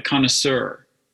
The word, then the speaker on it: connoisseur: Once in a while, there's a stressed schwa, and that's kind of weird, like connoisseur. Uh, that's one pronunciation of it is a stressed schwa there, and that's a little weird.